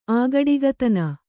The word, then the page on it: ಆಗಡಿಗತನ: kannada